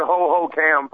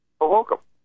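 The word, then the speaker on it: Hohokam: You gotta say it is one word, not three words. It's not Ho Ho Cam Park. – Well, how do you pronounce it? – Ho Hokum. – Oh, I always thought it was Ho Ho Cam.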